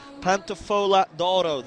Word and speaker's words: Pantofola d'Oro: It's pronounced Pantofola d'Oro, the golden slipper.